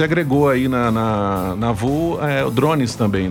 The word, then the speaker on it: Vault: Vu. Vu. Se escreve V-A-U. V-A-U-T. U-T, mas se pronuncia Vu Produções, né?